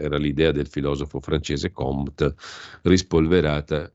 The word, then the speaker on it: Comte: attenzione con la M, non con la N, eh, perché sennò poi si legge Conte, Comte, Auguste Comte era un filosofo francese, mi sembra uno dei razionalisti, dei positivisti, no?